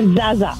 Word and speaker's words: zaza: Sí, so se pronuncia zaza, es Z-A-Z-A, zaza. – Zada. – Sí, es una palabra que Z-A-D-A, zada, zada.